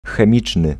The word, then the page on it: chemical: /kɛ.mɪ.kəl/